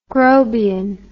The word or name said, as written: grobian